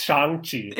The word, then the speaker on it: Shang-Chi: It's, it's, it's Shang. It's Shang Chi. It's not.